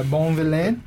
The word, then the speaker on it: Bonvillain: This is written by Brandon Montclair and Amy Reeder, art by Natasha Bustos and Tamara Bonvillain. You like that? – I always it's almost spelled like Bond villain. – Oh, yeah, a Bond villain. – That's a good way to remember that. – Yeah, Bonvillain.